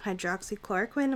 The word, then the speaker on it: hydroxychloroquine: So this week there seems to be a lot of debate around the drug hydroxychloroquine.